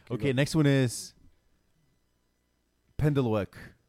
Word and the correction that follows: pendeloque: I want the origin, and I want you to say it again because I guarantee that's not how it's pronounced. – Okay, the word is pendeloque.